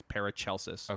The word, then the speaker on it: Paracelsus: I'm like 99% sure it is Paracelsus. – Okay, I've just always heard it Paracelsus, but it could be one of those Genghis, Genghis Khan kind of things.